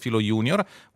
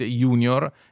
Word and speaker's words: junior: In italiano molti dicono junior e senior con una pronuncia adattata derivata dall'inglese per due parole che sono latine che si pronunciano evidentemente junior e senior. E quindi la parola è latina, la pronuncia del latino in questo caso è molto simile all'italiano o meglio il contrario, e quindi perché dire junior e senior?